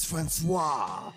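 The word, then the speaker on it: François: My name is Francois.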